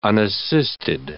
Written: unassisted

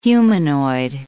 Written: humanoid

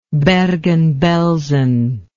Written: bergen belsen

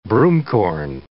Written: broomcorn

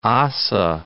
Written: Ossa, Mount

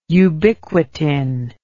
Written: ubiquitin

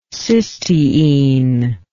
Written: cysteinic

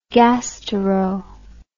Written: gastero

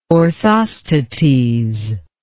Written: orthostates

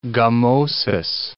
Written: gummosis

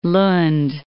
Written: Lund

